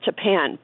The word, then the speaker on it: Tappan: It's pronounced Tappan, but actually, when we first moved up here, I thought it was Tappan. – So have you? – But everybody says Tappan.